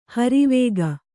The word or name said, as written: ಹರಿವೇಗ